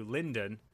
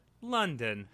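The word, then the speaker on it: Lindon: We cut to Linden, although some people pronounce it London, the capital of the High Elves.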